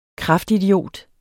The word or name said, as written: kraftidiot